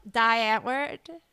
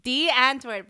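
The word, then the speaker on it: Die Antwoord: And I love, I would say one of D'Antwoord, if anyone has ever heard of them. D'Antwoord, but it's spelled Die, D-I-E, Antwoord.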